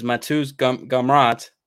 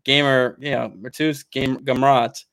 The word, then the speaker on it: Matheus Gamrot: Well, from there, I guess we could go to the next fight, which is Diego Ferreira versus Matheus Gamrot.